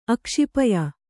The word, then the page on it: ಅಕ್ಷಿಪಯ: Kannada